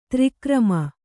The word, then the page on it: ತ್ರಿಕರ್ಮಿ: tri karmi